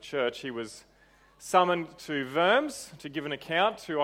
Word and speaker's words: Worms: It sounds like Diet of Worms, but it's called the Diet, which is a church council, and Worms, which is pronounced Worms,